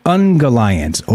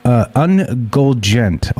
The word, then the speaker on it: Ungoliant: And yes, the name is pronounced Ungolgent, but it's spelled U-N-G-O-L-I-N-T. Ungoliant, I've heard. Ungoliant, I've heard as well.